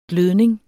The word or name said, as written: glødning